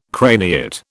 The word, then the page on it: craniate: British English